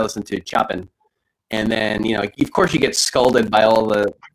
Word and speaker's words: Chopin: For which the entire presentation I pronounced his name Chopin without realizing. – You know, I picked the name from a list. Get it? No, for real. I picked it from a written list, so I didn't know it was actually pronounced Chopin. And the professor was like, "Hey, great, well researched and all. It's pronounced Chopin. You get a B."